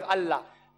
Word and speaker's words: Allah: The name, one of the attributes of God Almighty is Allah. They write it as I-L-A. But when you pronounce it, we have to tell them, pronounce it as Allah.